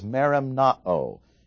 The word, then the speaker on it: merimnao: The Greek word for worry, the verb for it, is merimnao. And so in verse 6, he says, be anxious, merimnao, for nothing. In other words, if we engage in the merimnao, or the concern of verse 6, it's sin.